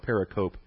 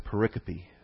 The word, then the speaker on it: pericope: I used to call it pericope, and then I exposed my foolish pronunciation at one point, and the man said, actually, it's pronounced pericope. So I've learned ever since then, pericope.